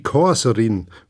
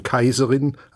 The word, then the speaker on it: Kaiserin: Liebe, sollten's halt nicht so schlecht Deutsch sprechen, sprechen immer die Korserin, muss hasen die Kaserin.